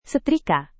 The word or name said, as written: setrika